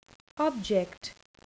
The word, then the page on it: object: noun